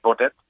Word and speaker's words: Baudet: So there's a new country, and I'm not sure if your listeners are familiar, but Thierry Baudet, I guess you pronounce it, Baudet, who's at the helm of this new party called Forum for Democracy.